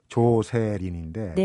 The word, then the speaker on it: 조세린: 일본에서 가타카나로 조세린, 그러니까 원래 이름이 조세린, 음. 조셀린. 음. – 조세... 원 발음 이제 조슬린. – 조슬린. – 네. – 조슬린인데 그 제일 가까운, 가까운 발음이 일본 말로 조세린이니까 네.